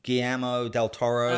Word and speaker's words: Guillermo del Toro: It's uh, Guillermo del Toro, okay.